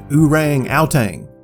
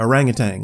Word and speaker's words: orangutan: So if y'all are ready, after reading Hop Frog by Edgar Allan Poe, my English teacher insisted we pronounce it orang outang instead of orangutan like he's supposed to.